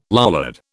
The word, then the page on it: Lollard: British English